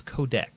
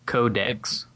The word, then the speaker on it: Codex: And I think it's codec, right? It's spelled C-O-D-E-X. It's one of those words that you don't ever really say a lot because you just read it online. I believe it's codec. – I wonder if it's in the dictionary. Codecs. – It really is codecs? – It says K-O-D-E-K-S. That's how I'd pronounce it.